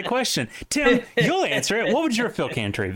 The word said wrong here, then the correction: phylactery: be? – My phylactery. – Is that how you say it? Phylactery.